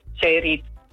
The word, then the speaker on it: Zeerijp: Zeerijp, Zeerijp. Nee. – Nou, als je Zeerijp zegt, dan benadruk je de tweede lettergreep meer dan de eerste lettergreep.